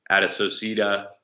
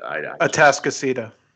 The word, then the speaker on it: Atascocita: Another one, Humble Atascocita, and I butchered that, but Atascocita. – Atascocita. I do that all the time.